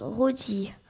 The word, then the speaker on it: Loti: Et oui, Loti, qui se prononce Roti.